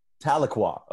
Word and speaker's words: Tahlequah: Tahlequah.